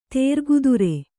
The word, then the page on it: ತೇರ್ಗುದುರೆ: kannada